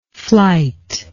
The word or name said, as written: flite